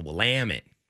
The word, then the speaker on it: Willamette: Yeah, it's the Willamette. You got a what? – When hard all, emphasis on the A.